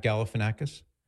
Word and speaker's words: Galifianakis: The actor Zach, I always trip over his name, Galifianakis. Is that how you say it? – Nice work. – Zach Galifianakis. – You did it.